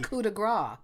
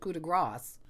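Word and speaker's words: coup de grâce: But a lot of people, we found this a lot of people insist on not pronouncing those final French syllables, thinking incorrectly. – Well, it's like coup de grace, instead of coup de grace.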